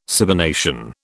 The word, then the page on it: cybernation: British English